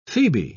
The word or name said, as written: Phoebe